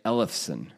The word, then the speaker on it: Ellefson: E-L-E-F-S-O-N. How would you say it? – I would say Ellefson.